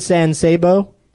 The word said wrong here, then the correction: San Saba: San Saba? No. – Oh, Alec Baldwin not wrong in San Saba. For a whole week now, I've been pronouncing it wrong.